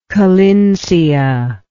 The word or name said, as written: collinsia